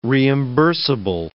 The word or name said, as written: reimbursable